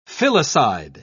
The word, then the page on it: filicide: British English